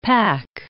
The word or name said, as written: pack